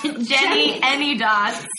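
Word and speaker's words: Jennyanydots: I don't know how to say this word, Janene Dots. – Jenny Any Dots.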